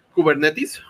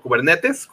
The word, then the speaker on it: Kubernetes: .NET 5 va a soportar Docker y Kubernetes, o Kubernetes, como quieran pronunciarlo.